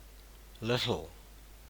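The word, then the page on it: little: New Zealand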